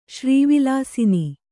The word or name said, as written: ಶ್ರೀವಿಲಾಸಿನಿ